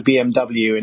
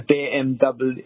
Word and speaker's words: BMW: They call it a BMW, and it's actually a BMW, a BMW, because in German, W is pronounced V.